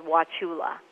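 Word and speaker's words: Wauchula: and the ranching town that the bad boy cowboy moves to is called Wachula. – Like, watch out.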